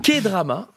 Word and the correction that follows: K-drama: je le prononce comme ça. – K-drama, K-drama.